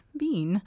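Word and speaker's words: Wein: Although all three books in the Codename Verity series by Elizabeth Bean, I looked that up, how to pronounce that, Bean.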